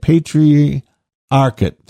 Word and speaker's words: Patriarchate: Patriarchate, how do you say that? Patriarchate, that's how you say it, of Alexandria.